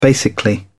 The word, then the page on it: basically: British English